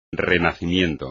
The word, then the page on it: renacimiento: espagnol